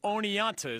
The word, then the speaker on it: Oneonta: SUNY Oneonta.